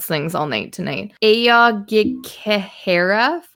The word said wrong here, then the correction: Aokigahara: Forest. Do you know how to say this? – One second. – Aoghikehara Forest. – Aokigahara. – Mm-hmm. Aokigahara. That sounds way better.